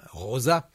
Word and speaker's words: Rosa: Lo pronuncio a la española, Rosa, como lo decimos nosotros.